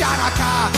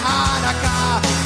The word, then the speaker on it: Hanukkah: It's not pronounced Hanukkah, the C is silent in Hanukkah.